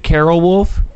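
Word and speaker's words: Caryl Wolf: Is it Carol Wolf? – It absolutely, you got it right.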